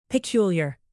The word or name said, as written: peculiar